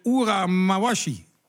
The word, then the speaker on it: Ura Mawashi: De Ura Mawashi.